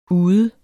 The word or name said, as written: ude